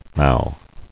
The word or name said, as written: Mown